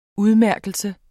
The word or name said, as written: udmærkelse